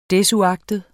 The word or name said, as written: desuagtet